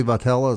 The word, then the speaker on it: Vitello: I think Tony Vitello, as I pronounce that right. – Yeah. Mm-hmm.